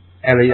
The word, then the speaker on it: Eli: Eli. – Yeah. – Or Ellie, sorry, the girl. – Ellie, that's right, yeah. Ellie, yeah.